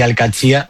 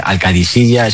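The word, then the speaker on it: Al-Qadsiah: Bueno, se suele decir Al-Katsia, pero también hay gente que dice Al-Kadisiya, es que es difícil, ¿eh? Ah. No es fácil, pero Al-Katsia es lo más fácil.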